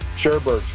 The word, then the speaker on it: Sherbet: There's only one pronunciation. Sherbet. – Thank you. – So you're just adding R's.